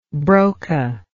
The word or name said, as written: Broca